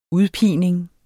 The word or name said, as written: udpining